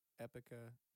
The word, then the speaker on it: Epica: Yeah, it's from a company called, I'm not sure how to pronounce it. It's Epica. Yeah. – E-P-I-C-A.